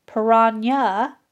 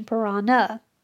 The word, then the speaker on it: piranha: Doctors Hoke and Mingers correctly pronounced the fish name as Piranha rather than the common mispronunciation Piranha.